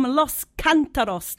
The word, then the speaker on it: Los Cantaros: You're listening to MKFM, and currently I am joined by Matías from Los Cantaros. Did I pronounce that correctly? – Yes, you did.